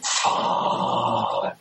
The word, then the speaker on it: Fandango: I like the idea to the person about that voicemail, he totally said the name wrong. It's Fandango.